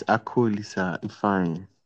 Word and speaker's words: Akulisa Ifeanyi: It's Akulisa Ifeanyi. – Akulisa. Like I call Lisa.